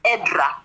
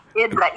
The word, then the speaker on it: Edra: My name is Edra. Edra, E-D-R-A.